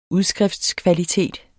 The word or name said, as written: udskriftskvalitet